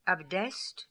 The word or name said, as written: Abdest